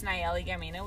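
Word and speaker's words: Nallely Gamino: My Instagram is Nayeli Gamino, which is just my full name.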